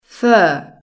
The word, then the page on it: fur: British English